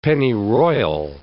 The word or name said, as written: pennyroyal